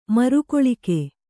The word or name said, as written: ಮರಕಾಳಗ